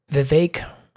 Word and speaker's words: Vivek: And I'm going to talk about Vivek rhymes with cake, Ramaswamy.